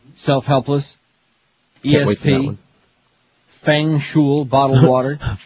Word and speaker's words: Feng shui: No, it's spelled here F-E-N-G. – That's Feng. – How you spell it, but it's pronounced Feng Shui, trust me.